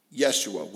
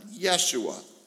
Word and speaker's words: Yeshua: So a great figure in Jewish history, and lots of people named their kids Yeshua, which is how Joshua was pronounced. They didn't have a hard J in their language. So the idea of saying Jesus or Joshua wasn't something they could do. It was pronounced with like a Y sound, Yeshua.